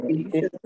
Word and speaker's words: Gift3D: It's spelled G-I-F-T-3-D, but it's pronounced Gifted.